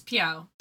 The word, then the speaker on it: Piao: Do you pronounce it Piao or P-E-O-W Studios? – It's Piao.